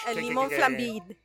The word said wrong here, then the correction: flambé: No, the flambé. It's the flambé. – It's a flambé. – It's like plan B.